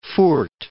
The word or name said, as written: Furth